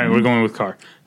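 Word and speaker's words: Kerr: I believe it's pronounced Carr, even though there's no A in it. – Yeah, it's an E. But everyone says Carr, so that's what I just said.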